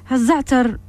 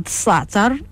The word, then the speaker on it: الزعتر: الزعتر نبتة عطرية وهي موجودة في المغرب، طبعا هناك من ينطقها الصحتر أو بالصاد الصعتر، وهو نبات عشبي ينمو بشكل عفوي.